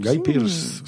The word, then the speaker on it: Guy Pearce: Guy Pierce, sí, Guy Pierce.